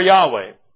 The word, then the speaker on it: Yahweh: And fire came out from the presence of Yahweh and consumed them, and they died before Yahweh. I'm reading the word the Lord in its Hebrew pronunciation.